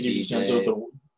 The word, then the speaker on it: DiVincenzo: Well, they have DiVincenzo, if I'm saying that right. – Yeah, Dante DiVincenzo.